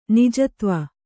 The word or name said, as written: ನಿಜತ್ವ